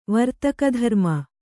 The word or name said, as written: ವರ್ತಕಧರ್ಮ